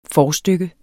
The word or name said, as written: forstykke